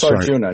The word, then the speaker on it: Arjuna: But well, it's A-J-U-N-A, right? – Yeah, it's Arjuna, just like the classic Indian myth.